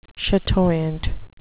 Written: chatoyant

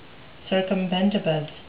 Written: circumbendibus

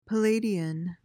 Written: palladian

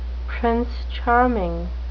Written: prince charming

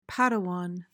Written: padawan

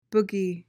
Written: boogie

